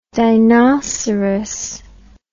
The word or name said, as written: dinoceras